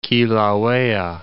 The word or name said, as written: Kilauea